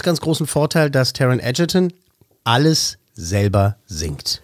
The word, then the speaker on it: Egerton: da hat er eben den jungen Taron Egerton kennengelernt. Der wird nicht Egerton ausgesprochen, sondern Egerton.